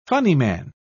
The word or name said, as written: funnyman